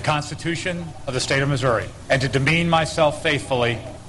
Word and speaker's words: Missouri: I say Missouri, which is to say I pronounce it correctly.